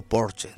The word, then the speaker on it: Porsche: Y en cuanto a nacimientos, en 1875 nació el ingeniero automotriz austriaco-alemán Ferdinand Porsche, o Porsche también se puede pronunciar en el castellano.